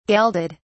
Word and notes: gelded: British English

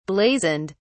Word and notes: blazoned: British English

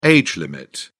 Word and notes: age limit: British English

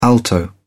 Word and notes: alto: British English

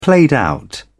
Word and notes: played-out: British English

